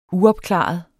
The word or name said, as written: uopklaret